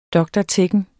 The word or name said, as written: dr.techn.